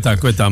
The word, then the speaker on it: Ceuta: Ik weet niet waarom je no man maar wat gebeurt er in de exclave van Spanje, Ceuta, hoe spreek je dat? – Ceuta, madame Ceuta.